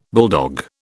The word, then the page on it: bulldog: British English